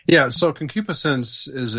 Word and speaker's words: concupiscence: Concupiscence, see, I said it right that time.